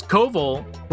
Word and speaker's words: Koval: Because Koval rhymes with oval.